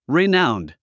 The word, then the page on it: renowned: rináund; リナァウンド